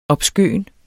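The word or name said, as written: OBSKØN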